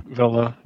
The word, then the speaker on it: Villa: Is it, I don't even know how to say it, Aston Villa or is it Villa? – Villa. – Aston Villa, yeah.